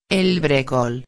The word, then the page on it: el brecol: espanhol